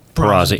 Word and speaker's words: Perazzi: Did I say that right? Perazzi. – Perazzi. It depends on what part of the country you're from.